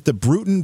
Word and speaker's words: Bruton: Daniel, I want to find out more about the Bruton, B-R-U-T-O-N, Parish Church? – Yes. – Is that how it's pronounced? – That's right.